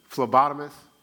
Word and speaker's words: phlebotomist: Phlebotomist? Did I say it right? All right, thank you, draw blood.